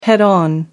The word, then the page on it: head-on: American English